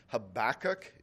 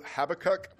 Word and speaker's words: Habakkuk: And so let me just say either one of them is technically correct. Habakkuk is the Americanized version of the Hebrew Habakkuk, which emphasizes the first syllable.